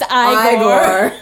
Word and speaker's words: Igor: No, it's pronounced Igor. – You're right. – They told me it was Igor. Well, they were wrong, weren't they? – Oh, it's good.